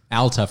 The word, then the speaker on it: Alta: Alta for those looking to Google it, not Alta. Anyway, carry on. – Yeah, okay. Sure. – A-L-T-A. You're probably better just A-L-T-A, to spell it instead of correcting pronunciation.